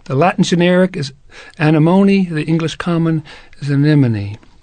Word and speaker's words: anemone: If you look up that genus in a dictionary of plant names, you'll probably see that the accent is on the M-O, so anemone. But, not anemone.